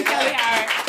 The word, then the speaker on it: Kellye: I've been Kelly Howard. – Kelly Howard, everybody. – And if you decide to look me up or anything, my name is spelled like Kanye, but Kelye, so it's K-E-L-L-Y-E.